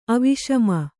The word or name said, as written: ಅವಿಷಮ